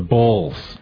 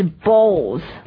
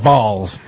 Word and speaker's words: bulls: Yeah, the Chicago Bulls, the Bulls. – 公牛就是 bulls, 对吧、 B-U-L-L.、 Bull with a U, bulls, not balls, bulls. – 对这个发音要很注意不是球也不是蛋是公牛 bulls.